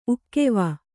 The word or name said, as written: ಉಕ್ಕೆವ